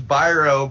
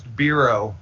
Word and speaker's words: Biro: It's actually, my whole life I was told it was Biro, but like 3 years ago, my father told me that it's actually pronounced Biro.